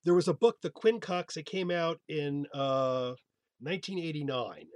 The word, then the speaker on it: Quincunx: The Quincunx, yeah. – Well, there you go. So the word Quincunx, I mean, is it pronounced the way it looks? Quincunx? – Yes.